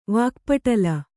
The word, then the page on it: ವಾಕ್ಪಟಲ: Kannada